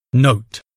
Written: note

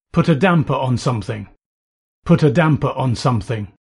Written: put a damper on

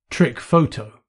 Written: trick photo

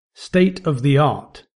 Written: state of the art

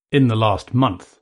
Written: in the last month

